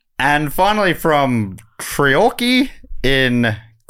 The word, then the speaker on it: Treorchy: Treorchy.